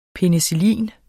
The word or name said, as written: penicillin